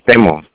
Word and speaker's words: Temo: You can use the short name, Temo. T-E-M-O is the last.